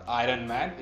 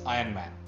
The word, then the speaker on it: Iron Man: Like, it's not Iron Man, it's Iron Man. You don't pronounce the R,